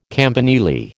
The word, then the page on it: campanile: American English